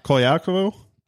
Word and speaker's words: Colaiacovo: Colaiacovo, yeah, that's it.